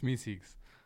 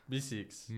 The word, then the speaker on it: Meeseeks: It's Minxies. – It's Meeseeks. – Meeseeks.